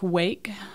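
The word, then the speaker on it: Rwake: Sludge metal band of Little Rock, Wake, spelled with an R, but the R is silent.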